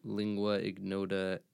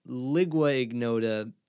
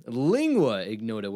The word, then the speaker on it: Lingua Ignota: Everybody, before you listen to the OG pilot episode, which won't come out for a hot sec, I pronounce Lingua Ignota, Ligua Ignota.